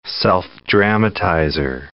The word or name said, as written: self-dramatizer